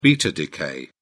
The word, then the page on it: beta decay: British English